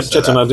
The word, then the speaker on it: Chettinad: Am I saying that right? – Yep, Chettinad, yeah.